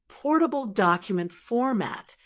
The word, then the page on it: portable document format: American English